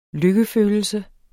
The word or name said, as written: lykkefølelse